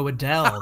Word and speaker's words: Adell: Oh, it rhymes with bell. How about Joe Adell?